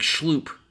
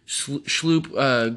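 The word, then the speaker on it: Schlupp: Oh, it was like Schloop. It was Schloop. That's right. Schloop.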